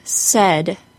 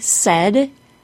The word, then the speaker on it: said: Notice that the word said, S A I D, rhymes with bed. Said, bed. Repeat those two words after me. Said, bed. If you pronounce S-A-I-D with a long A, it will sound like you are saying said, S-A-Y-E-D.